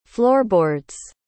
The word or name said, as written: floorboards